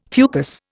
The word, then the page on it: fucus: Indian